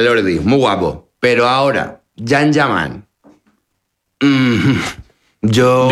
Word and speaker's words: Can Yaman: Se escribe Can Yaman, se pronuncia Jan Yaman.